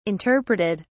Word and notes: interpreted: British English

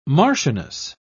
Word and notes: marchioness: British English

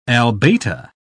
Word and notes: albata: British English

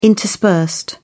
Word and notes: interspersed: British English

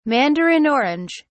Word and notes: mandarin orange: British English

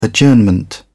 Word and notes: prorogue: British English

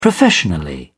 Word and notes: professionally: British English

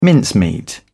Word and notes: mincemeat: British English

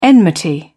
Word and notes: enmity: British English

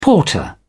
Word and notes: porter: British English

